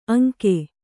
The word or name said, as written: ಅಂಗ